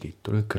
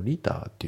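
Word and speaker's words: liter: How much どれくらいの量 About a little 1 リットルくらいですですよね。まああと発音ですけど About a のところがリンキングで About a ですね。 About a じゃなくて About a で、あとそのリターっていうね、日本語だとねリットルっていう感じで発音するんですけど、これ英語だとねリターっていう感じなんですよね。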